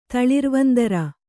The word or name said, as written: ತಳಿರ್ವಂದರ